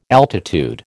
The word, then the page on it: altitude: American English